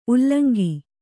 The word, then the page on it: ಉಲ್ಲಂಗಿ: ullaŋgi